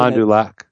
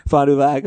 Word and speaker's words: Fond du Lac: This is from Devin, and I don't know how to pronounce this, but it looks like Fond du Lac, but I can't imagine. – Fond du Lac. – Fond du Lac, okay.